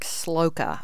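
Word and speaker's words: Slouka: I want to save that for a time, but All right, there's another book called Brewster by Mark Sloka. I believe that's the way you pronounce it. It's S-L-O-U-K-A.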